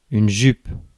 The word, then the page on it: skirt: British English; American English; [skɜː(r)t]; /skɜːt/; /skɜ˞t/; /skɝt/